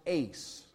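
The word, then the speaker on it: eis: And the Greek word in Acts 2:38 for this little word for is pronounced ace.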